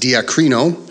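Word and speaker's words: diakrino: This is a Greek word and it comes from a word called that's pronounced diacrino, which is to separate thoroughly.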